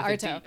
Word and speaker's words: Artaud: Yeah, Artaud. – Okay, I guess the D is silent.